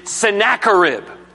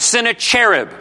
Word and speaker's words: Sennacherib: His name is Sennacherib. You may be tempted to pronounce it Sennacherib, but there is nothing angelic about this man.